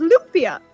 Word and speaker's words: lumpia: Apparently, I pronounced lumpia wrong. It's pronounced lumpia. Source of my Filipino-American wife.